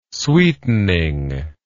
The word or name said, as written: sweetening